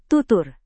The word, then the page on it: tutur: indonesian